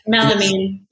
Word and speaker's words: melamine: It's called like Miramine. I never say it right. My mom gets mad at me every time. But then Melamine.